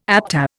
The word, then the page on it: ABTA: Indian